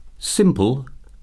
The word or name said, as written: simple